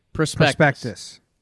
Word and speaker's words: Prospectus: You put a lot on the O there. – Baseball Prospectus? – Yeah, it's still a little heavy on the O, but you're there. – Prospectus. – Pretend the O is like a silent U. Prospectus.